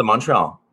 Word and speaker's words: Montreal: No, it's a Montreal. It's okay. the right way to say in English is Montreal.